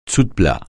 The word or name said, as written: dessous-de-plat